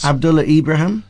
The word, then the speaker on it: Abdullah Ibrahim: Abdullah Ibrahim.